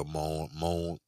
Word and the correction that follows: mourn: well, how you say it? – Mourn. – Excuse me.